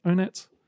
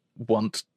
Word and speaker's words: Onett: It should be Want, kind of, because, you know, all of the town names in Earthbound are like sort of like fun little plays on things.